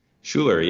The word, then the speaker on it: Schuller: Schuller, yes. Schuller.